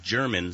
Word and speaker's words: Jermyn: It was a little town called Jermyn, spelled with a J. Uh-huh. Kind of like genuine.